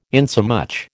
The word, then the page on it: insomuch: American English